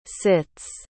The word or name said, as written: sits